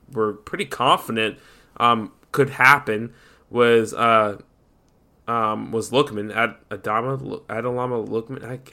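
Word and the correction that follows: Ademola: Adamola Lookman? – Yeah, Adamola.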